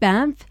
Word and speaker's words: Banff: Banff.